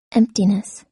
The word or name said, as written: emptiness